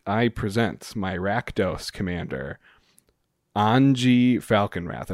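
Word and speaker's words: Anje: I believe Anji is technically the correct pronunciation, but it's not Anya. – I call her Anya, but I think it's supposed to be Anji. – Okay. – Like they said it was supposed to be like Schwabish German, like Southern German pronunciation, like Anji.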